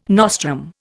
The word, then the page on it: nostrum: Indian